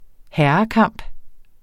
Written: herrekamp

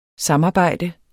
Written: samarbejde

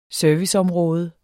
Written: serviceområde

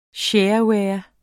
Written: shareware

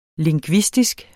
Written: LINGVISTISKE

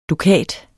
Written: dukat

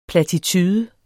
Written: platitude

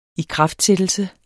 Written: ikraftsættelse

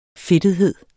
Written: fedtethed

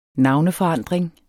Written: NAVNEFORANDRINGEN

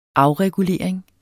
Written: afregulering

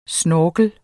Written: snorkel

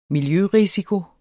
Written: miljørisiko

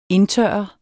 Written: indtørre

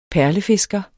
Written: perlefisker

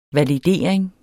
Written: validering

